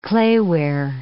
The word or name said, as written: clayware